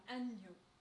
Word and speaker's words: agneau: But being an Englishman, he has difficulty pronouncing the sound ny in the French word for lamb, agneau. I'm not going to go into the reason why the spelling doesn't match the pronunciation at this particular period.